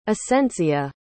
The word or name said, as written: asentzio